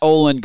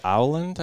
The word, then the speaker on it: Öland: I think it's with the umlauts, it's pronounced Öland, I think, is kind of how you say okay.